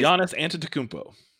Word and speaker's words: Giannis Antetokounmpo: You can say Giannis Antetokounmpo.